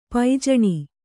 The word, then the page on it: ಪೈಜಣಿ: kannada